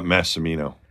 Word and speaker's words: Massimino: Massimino.